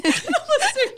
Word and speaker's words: Lasso: It's just a mispronunciation. Lasso, just like a cowboy.